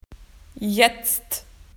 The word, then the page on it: now: Australian English